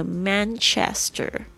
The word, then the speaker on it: Manchester: 那这个因为他是英国人嘛，所以这个 R 的这个 A 的声音呢会发 R，那如果是这个美式发音的话会发成这个 Manchester。